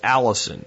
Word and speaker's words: allicin: So one of the big things garlic has, and it's why it has that smell, is a compound known as allicin. You pronounce it like a girl's name, but it's spelled A-L-L-I-C-I-N.